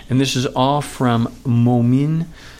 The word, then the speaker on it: Momin: However, the name of the brand is Moment, M O M I N. and I believe it's pronounced Momin. I hope I'm pronouncing it correctly.